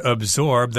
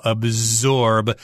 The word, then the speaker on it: absorb: The dictionary sometimes says this is pronounced absorb, but I tend to make a Z sound in there, and I think a lot of people do as well. Absorb. I've heard that more often than absorb.